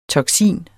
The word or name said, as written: toksin